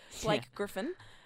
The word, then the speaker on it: Blake Griffin: Great Griffin. I didn't even say his name right in the end. Blake Griffin.